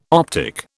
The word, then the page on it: optic: British English